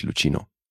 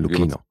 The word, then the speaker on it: Luchino: Ja, jetzt darf ich dich auch mal wieder korrigieren, weil der Herr heißt nicht Luchino. – Heißt nicht Luchino Visconti? Nee, Luchino. – Luchino wird's ausgesprochen, okay.